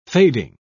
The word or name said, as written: fading